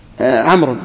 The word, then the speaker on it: عمرو: عمرو عمرو نائب فاعل مرفوع علامة رفعه الضم نعم لا ليش مقدرة ظاهرة الواو هنا ما تنطق الواو ما تنطق إنما وضعت الواو للفرق بين عمر وعمر